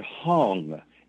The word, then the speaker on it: Hong: You inhale and say the word Hong, H-O-N-G, just like in Hong Kong.